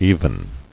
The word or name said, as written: Evenly